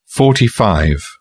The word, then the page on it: 45: British English